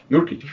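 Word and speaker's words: Nurkić: Nurkic.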